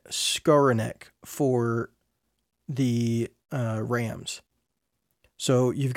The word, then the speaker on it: Skowronek: Yeah, I think Ben Skowronek is a good complementary piece.